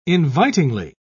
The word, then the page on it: invitingly: British English